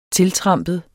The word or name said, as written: tiltrampet